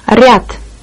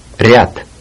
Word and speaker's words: Ряд: Ряд. – Ряд.